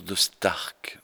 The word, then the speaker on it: de Staercke: On prononce de Stark, non pas que j'y tienne spécialement, mais simplement parce que le E derrière le A allonge le son A en flamand, c'est du vieux flamand.